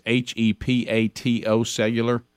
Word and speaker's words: hepatocellular: It's HEPATO cellular. Mm-hmm. Is that heptocellular? – Yeah, cancer. – Hepatocellular is what is the phonetic spelling.